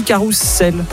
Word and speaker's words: carrousel: Le carrousel, ça s'écrit avec un seul S. Et pourtant on dit carrousel.